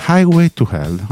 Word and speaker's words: Highway to Hell: Ho detto bene, Highway to Hell. Ah, ok, grazie.